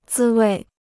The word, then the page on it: 滋味: zī wèi